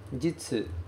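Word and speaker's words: jitsu (日): Só que a pronúncia que vai aparecer aqui pra gente hoje, ela é a pronúncia jitsu. Jitsu. Não é a pronúncia mais comum. A gente está acostumado a ver esse kanji como niti, ou como hi, ou então como bi.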